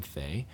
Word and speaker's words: Phai: I'm the only Korean with that last name, Phai, because my parents don't know how to spell. And it's supposed to be Bae, like B-A-E. Ooh. But then they spell it P-H-A-I.